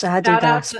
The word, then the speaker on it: Adidas: It's Adidas, by the way. – Yeah. – It's Adidas. – Yeah. – You and your Scottish pronunciations. Adidas.